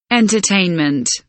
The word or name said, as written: entertainment